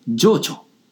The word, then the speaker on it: 定朝: それが定朝という人でした。定める朝と書いて定朝。定朝は運慶快慶よりおよそ 200 年前、平安時代中期の人です。現在に残る代表作としましては、平等院鳳凰堂の国宝、阿弥陀如来像などが挙げられます。定朝